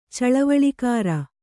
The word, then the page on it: ಚಳವಳಿಗಾರ: caḷavaḷigāra